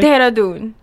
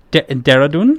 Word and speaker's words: Dehradun: Sorry, can you say the name again? Can I pronounce it correctly? – Dehradun. – Dehradun? – Yeah, you got it.